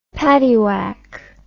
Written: paddywack

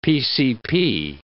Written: PCP

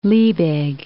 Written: Liebig

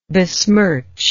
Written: besmircher

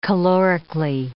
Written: calorically

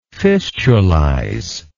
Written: fistulization

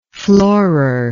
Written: floorer

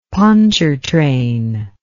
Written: pontchartrain

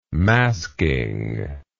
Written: masking

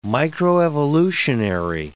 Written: microevolutionary

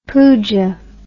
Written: pooja

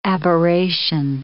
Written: aberration

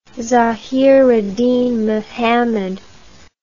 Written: zahiruddin muhammad

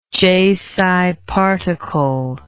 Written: j psi particle

